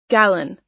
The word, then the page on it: gallon: American English